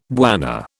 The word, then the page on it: bwana: British English